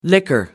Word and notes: liquor: American English

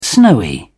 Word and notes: snowy: British English

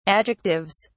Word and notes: adjectives: British English

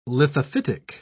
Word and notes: lithophytic: British English

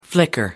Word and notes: flicker: American English

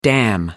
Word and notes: dam: American English

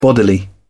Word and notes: bodily: British English

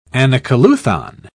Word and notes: anacoluthon: British English

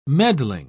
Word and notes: meddling: British English

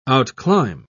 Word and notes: outclimb: British English

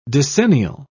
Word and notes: decennial: British English

